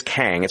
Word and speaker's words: Kang: Instead, it's got the letters Q-E-N-G, which is Kang. And not spelled the way that we, you know, spell Kang, K-A-N-G, but it is Kang. It's pronounced Kang.